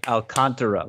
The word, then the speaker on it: Alcantara: Alcantara. – Oh. – Or I'm sorry, Alcantara. Let's say it right for once in our lives.